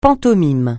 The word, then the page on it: pantomime: francais